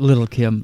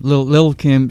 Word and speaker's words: Lil' Kim: Yeah, you better say that right. – Yeah, I know, right? It's not Lil' Kim. Lil' Kim, she did 1 year in prison for perjury.